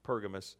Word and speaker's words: Pergamos: And in Revelation chapter 2, verse 12, Pergamos is the right way to say it, Pergamos.